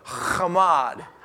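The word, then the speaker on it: Chamad (חָמַד): Now that word choice that was translated into English from the Hebrew is the Hebrew word kamad. Or if you want to truly pronounce it like the Hebrews did, you got to have that, like you're clearing your throat. Chamad. Chamad.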